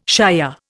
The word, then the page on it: shier: Indian